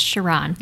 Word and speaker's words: Charron: It's Sharon, Larry. – Did I say Sharon? – You did. It's just ingrained. – And I tried to practice it beforehand. So and your name is spelled Charron. That's the way it'll show up on the ballot. – Yes. – And Sharon is how it's pronounced.